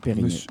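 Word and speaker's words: Periné: La chanteuse a fait des études au lycée français, donc j'imagine elle le prononce avec l'accent français. – Donc Périné.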